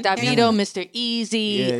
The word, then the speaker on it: Davido: How do you say, I call him Davido. How is Davido. – Davido. I just learned that.